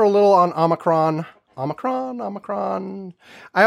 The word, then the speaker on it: Omicron: It's Omicron or Omicron. Uh, and those are apparently both legitimate pronunciations, depending on accents and where you live, blah, blah, blah. But uh, Omnicron is wrong. Unicron is wrong.